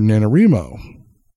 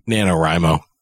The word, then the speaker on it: NaNoWriMo: And that was the story that I wrote for NaNoWriMo in November, which is National Novel Writing Month. – You're the first person I've ever heard pronounce it that way. – How is it normally pronounced? – NaNoWriMo.